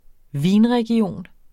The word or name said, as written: VINREGION